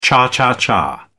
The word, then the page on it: cha-cha-cha: British English